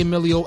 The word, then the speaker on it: Emillio: Once again, that's at the Emilio Akbar on Twitter, spelled E-M-I-L-L-I-O, for those of y'all that get my name wrong, but you know, it's all good.